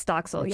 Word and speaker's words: Doxil: And one of the drugs in short supply is called, if I'm pronouncing this correctly, correct me if I'm wrong, Doxil? – Yes, Doxil, yes.